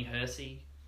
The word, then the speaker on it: Hersee: Danny Hersey, H-E-R-S-E-E.